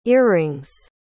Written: earrings

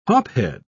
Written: hophead

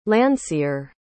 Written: landseer

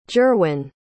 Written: gerwyn

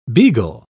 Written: beagle

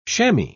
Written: shammy

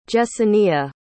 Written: Jessenia

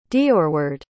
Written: deorward

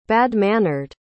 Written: bad-mannered

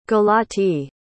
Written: galatee

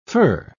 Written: fir

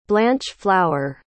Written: blancheflour

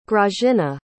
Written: grazyna

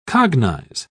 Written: cognize

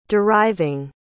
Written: deriving

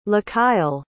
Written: Lakyle

